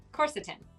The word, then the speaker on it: quercetin: So like a corset. That's what I have to remember. Corset. Quercetin. – Yeah, believe me, when I was in med school learning about it, I pronounced it wrong for almost a year.